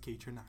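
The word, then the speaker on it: Kaytranada: But yeah, if you go and find him, his name is, in all capitals, it's K-A-Y-T-R-A-N-A-D-A. And it's pronounced K-Dronata.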